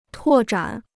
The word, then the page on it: 拓展: tuò zhǎn